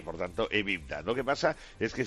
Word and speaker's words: EBITDA: que es el acrónimo EBITDA. – EBITDA, sí.